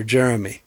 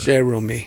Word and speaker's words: Jerome: If you can, the mean streets of, at one point I thought it was, because we pronounce things weird around here, Jeremy. – No. – Just a unique approach to Jeremy.